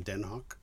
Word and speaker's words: Den Haag: I knew it was in the Netherlands, but it's not The Hague, no. – Netherlands. It's in Den Haag. – Well, that's how you say it when you're in... Native speakers say... – Oh, Den Haag. – Den Haag. – Den Haag. Yeah. I get it. – Yeah, Den Haag. But they say it like this. They say it Den Haag.